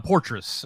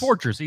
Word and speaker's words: Portes: But, however, Portress. Just rhymes with Portress. Easy to remember that way.